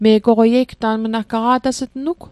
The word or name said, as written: me' goqwei